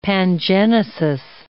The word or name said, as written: pangenesis